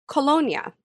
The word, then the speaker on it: kolonya: which is colonia. So it's like the word cologne, except it's spelled K-O-L-O-N-Y-A, and it means cologne.